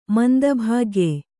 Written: ಮಂದಭಾಗ್ಯ

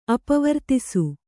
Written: ಅಪವರ್ತಿಸು

